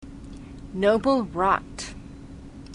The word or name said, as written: noble rot